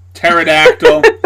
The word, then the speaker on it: pterodactyl: Basically, they're like, you know, little T-Rex, little Petrodactyl, little Stegosaurus, like little guys. – Did you just fucking say Petrodactyl? – Pterodactyl. – You fucking heathen.